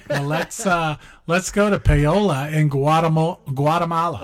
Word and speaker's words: Paola: I think it's Paola, P-A-O-L-A, in Guatemala. – Oh, well, in Spanish, people with that name, they call each other Paola. – Oh, so it is Paola. Yeah. Okay. – And some people say Paul, like almost like a boy's name, Paul.